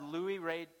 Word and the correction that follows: Louis-Dreyfus: Or Julia Louis-Dreyfus, there you go.